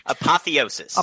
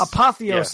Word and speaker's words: apotheosis: Apotheosis. – Apotheosis.